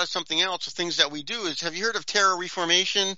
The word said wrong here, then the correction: terraforming: And I'm probably saying it wrong right now, but we have this plan. – Terraforming? – Terraforming? Terraforming, that may be – we have this plan where we can go to Mars and we think we can change the atmosphere there.